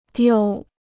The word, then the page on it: 丢: diū